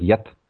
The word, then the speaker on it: get: And in Scandinavian languages, a G is often pronounced like a Y, so it's really the word get, is which meant goat, is pronounced like yet.